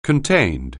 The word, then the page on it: contained: anglais